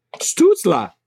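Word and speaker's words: Stützle: Stutzla. – There you go. – That's what Steve said. He wrote it out phonetically.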